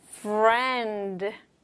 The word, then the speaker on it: friend: F-R-I-E-N-D. Once again. F-R-I-E-N-D. And the sounds. F. 니까 f, f 이런 소리가 나야 되는데 아랫입술이 윗니에 f 닿았다가 떨어져야 돼요. f f 윗입술이 아니라 윗니에 닿아야 되는 거 don't forget. f f r 있으니까 r 혀 굴리는 거 있고 n이니까 니은 발음. n n D라서 디귿 D D F R E N D 합치면 Friend Friend Friend Friend Okay, I'll put friend back in the box as well.